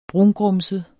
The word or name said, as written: brungrumset